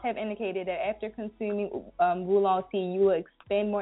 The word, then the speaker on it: oolong: Wulong tea, it's spelled O-O-L-O-N-G.